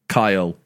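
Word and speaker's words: X Æ A-12: X is the Greek letter pronounced Kai. – Okay. – AE pronounced I, like Kyle. – Yeah. – Kai, like that. Yeah. A12, 12th letter of the alphabet is L, Kyle.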